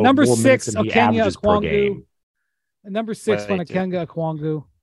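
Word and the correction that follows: Onyeka Okongwu: I mean, it's just the worst pronunciation of his name of all time. – Onyeka Okwongwu.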